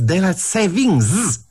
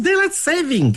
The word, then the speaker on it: daylight saving: Them saying is daylight savings. It's not. It's daylight saving. Get it right.